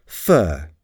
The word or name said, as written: fur